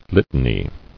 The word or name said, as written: Litany